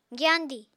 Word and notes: gyandi: apatani

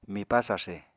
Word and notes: mipá sarse: Apatani

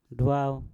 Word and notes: dwau: Mpur

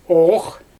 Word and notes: oox: Xyzyl

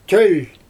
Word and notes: tølʲ: Xyzyl

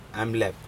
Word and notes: amlep: Aneityum

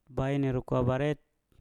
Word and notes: bain erokwa baret: Mpur

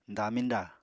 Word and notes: damiñda: Apatani

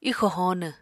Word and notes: ixò hóonw: apatani

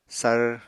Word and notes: sar: apatani

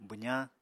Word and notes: bwzañ: apatani

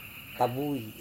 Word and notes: tavui: Serewen

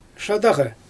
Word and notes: ʃadaɣa: Xyzyl

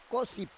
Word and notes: kosip: Roon

